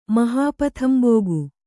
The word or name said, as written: ಮಹಾಪಥಂಬೋಗು